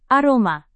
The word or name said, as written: aroma